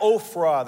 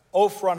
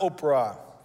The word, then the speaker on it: Ophrah: Judges 6, verse 11 says the following The angel of the Lord came and sat beneath the great tree at Ophrah. That's Ophrah, not Oprah.